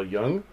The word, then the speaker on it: Jung: know much about uh, Carl Jung.